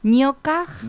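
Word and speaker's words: ñoqaq: Ñocaj. – Ñocaj con la pronunciación con J, se escribe con Q.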